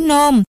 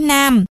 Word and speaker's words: nôm: Nguyễn Thuyên nghĩ ra ngay, vậy tên cuốn sách này phải đọc là chữ nôm chứ không phải chữ Nam, mà nôm thì khó gì, thêm một chữ khẩu bên chữ Nam thôi mà.